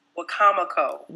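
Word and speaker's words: Wicomico: And she works with the Deputy Director of WMICO. Correct me if I say it wrong. – You say it wrong. It's WCOMICO.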